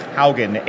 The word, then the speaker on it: Haugen: My last name is Haugen, H-A-U-G-E-N.